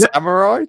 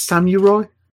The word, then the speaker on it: samurai: When this happened in the last podcast, he went samurai. It's just samurai. Just say it right. – It's samurai.